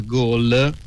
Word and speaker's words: Galle: E allora, all'interno del paese mi sono mosso con dei treni, in particolare sulla costa occidentale, il tratto da Bentosa a Gol, si scrive Galle sulla mappa ma si pronuncia Gol.